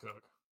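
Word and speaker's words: Koch: There's no way that last name is pronounced Cook. – How do you think it's pronounced? – It's pronounced Cock. It has to be. – Okay. – It's definitely not.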